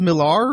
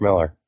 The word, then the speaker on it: Millar: Is it pronounced Millar or is it pronounced Miller? I've always wondered that. – Miller, it's Mark Miller. – It's M-I-L-L-A-R. – It's Miller.